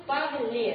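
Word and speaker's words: parler: Но в этом окончании буква «р» не читается. Значит, глагол «разговаривать», помните, у нас будет «парле».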